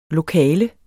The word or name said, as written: lokale